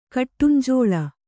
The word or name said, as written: ಕಿಡಿಂಜೆಲು